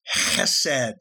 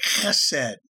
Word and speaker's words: chesed: The Hebrew word is chesed. That's how the Jews pronounce it, chesed. It's spelled H-E-S-E-D, and it means covenant loyalty.